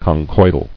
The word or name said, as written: Conchoidal